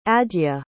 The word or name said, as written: adya